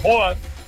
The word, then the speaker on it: Haaland: porque el Haaland, que por cierto, en teoría no se debería decir Haaland, deberíamos de llamarlo Hoag, o al menos así es la correcta pronunciación,